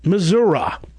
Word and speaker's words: Missouri: Third, anybody who lives in a town in Missouri, the size of Ebbing, is going to pronounce it Missouri.